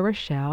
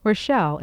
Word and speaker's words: Rochelle: Of course, you know my name is Rita Rochelle, and I'll spell that for you for our new listeners. Rita is R-I-T-A. Rochelle is R-O-C-H-E-L-L-E.